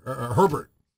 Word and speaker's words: Herbert: the young Herbert. No, he actually pronounces the R.